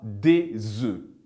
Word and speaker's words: œufs: On dira « un œuf », mais au pluriel, à la prononciation, on dira « des œufs ».